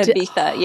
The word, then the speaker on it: Ibiza: We are like in Ibiza. Is that how you say it? – Yeah, Ibiza, yeah.